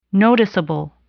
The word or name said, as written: noticeable